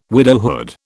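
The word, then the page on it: width: British English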